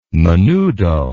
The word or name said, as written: menudo